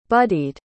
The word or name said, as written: buddied